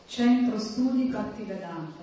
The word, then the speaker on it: Centro Studio Bhaktivedanta: Say it properly. – Centro Studio Bhaktivedanta. – That's it in Italian.